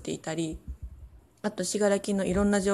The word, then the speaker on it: 信楽: で、最初に言ってたしがらきっていう漢字なんですけど、どう書くかっていうと、信じるに楽しいって書いてしがらきって読むんですけど、そうなんです。